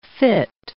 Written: fit

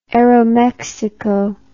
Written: aeromexico

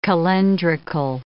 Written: calendrical

